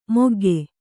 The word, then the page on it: ಮೊಗ್ಗೆ: mogge